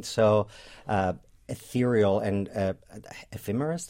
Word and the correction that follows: ephemeral: That's how you say it? – I think ephemeral. – Yeah, ephemeral.